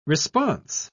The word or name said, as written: response